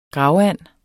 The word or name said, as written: gravand